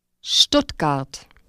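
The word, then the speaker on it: Stuttgart: Сочетание звуков S, T просто добавляет T к звуку H. – Шт. Штутгарт.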